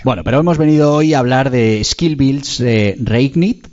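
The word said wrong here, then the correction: SkillsBuild Reignite: que no sé si lo he pronunciado bien. Cuéntanos, ¿qué es exactamente? – Skillsville Reignite.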